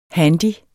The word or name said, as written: handy